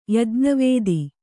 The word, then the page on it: ಯಜ್ಞವೇದಿ: Kannada